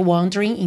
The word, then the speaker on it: wandering: Repara que você não diz wandering, tá? Você pode ignorar essa letra E aí da palavra wandering, então fica wandering, wandering in the night.